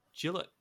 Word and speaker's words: Gillett: Um, and is that the game we've got uh, Mr. Jarred Gillett rhymes with Philip actually on that game yep.